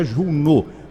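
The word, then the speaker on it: Juneau: A profissional da saúde foi vacinada ontem na cidade de, eh, parece que a pronúncia é Juno, capital do Alasca, no segundo dia da campanha de vacinação contra a Covid iniciada nos Estados Unidos.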